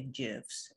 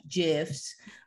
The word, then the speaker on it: GIFs: It was like, I guess like 2003, it was not like how you make gifts in Photoshop. And I don't know why I'm saying gifts, because I say GIFs, and that's the way he said is the correct way to say it. So GIFs.